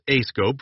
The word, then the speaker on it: ASCOPE: Commanders typically analyze civil considerations using six factors known collectively as ASCOPE and pronounced as ASCOPE for areas, structures, capabilities, organizations, people, and events.